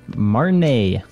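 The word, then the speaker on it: Martinaise: how do you pronounce it? – Marnay.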